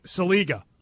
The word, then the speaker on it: Saliga: Last time I checked, there is no N in his name anywhere. It's not Salinga, it's Saliga.